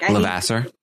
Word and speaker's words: Levasseur: How do you pronounce Derek's last name? – Like, Levasseur. Levasseur. Oh my God. All this time I've been like Levasseur.